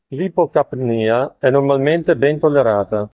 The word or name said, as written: normalmente